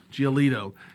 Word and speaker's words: Giolito: How do you say it? Giolito. You're the baseball guy. – I think it's. – Giolito. – Giolito.